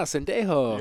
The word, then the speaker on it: Sendejo: Okay, then we got Andrew Sendejo. I don't know if I said that right. – Yeah, it is. That's it. – Nah, Sendejo.